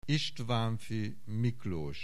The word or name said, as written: Istvanffy Miklos